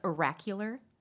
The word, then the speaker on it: oracular: And all we know is that it just has, how do you pronounce it? Oracular. – Oh, nice. – How's that? – Oh, that's very good. That's a great word. – Oracular properties. – Based on the word oracle.